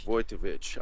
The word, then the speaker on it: Wojtowecz: Wojtowecz.